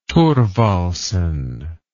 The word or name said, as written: thorvaldsen